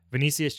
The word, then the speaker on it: Vinicius: Vinicius Jr. Am I saying his name right, by the way? – Yeah.